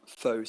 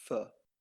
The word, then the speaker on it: pho: I'm just going to say it as I think most people would read it, but I know it's incorrect. So I would like a bowl of pho soup, but I think it's actually pronounced pho.